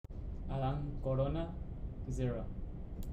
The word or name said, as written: Adan Corona